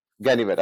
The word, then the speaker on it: Ganivet: How do you pronounce that name? Ganivet. I just want to make sure. Okay, great.